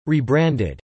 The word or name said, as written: rebranded